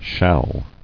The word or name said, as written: Shall